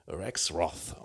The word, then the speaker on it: Rexroth: Wir hatten mal einen Wirtschaftsminister, der Rexroth hieß, aber dieser Mann wird dann amerikanisch Rexroth ausgesprochen, mit dem Marriage Blues von 1960, eine Platte, die live aufgenommen wurde, zusammen mit einem Kollegen und sehr jazzig angehaucht.